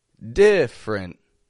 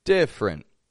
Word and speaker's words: different: First off, we have different. Spelled with three syllables, but we drop that one E in the middle, just two quick syllables. Different.